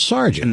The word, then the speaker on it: sergeant: That's S-E-A-R. One of these. No, it's S-E-R. – And then E-A-N-T, right? – Yeah. S-E-R-G-A. It would be like sergeant. – A lot of those with but it's pronounced sergeant.